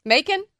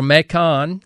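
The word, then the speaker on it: Mâcon: Macon? – That's the way you pronounce it in Georgia. – Absolutely. – Or Macon is the way it's closer to the way the French pronounce it.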